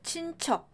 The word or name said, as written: 친척